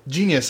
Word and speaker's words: Jeanius: Genius.